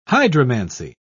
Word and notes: hydromancy: British English

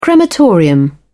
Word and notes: crematorium: British English

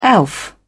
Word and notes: elf: British English

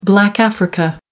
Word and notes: Black Africa: American English

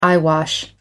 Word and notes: eyewash: American English

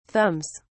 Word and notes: thumbs: British English

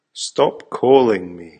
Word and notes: stop calling me: American English

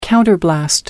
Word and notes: counterblast: American English

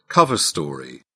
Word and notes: cover story: British English

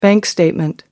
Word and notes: bank statement: American English

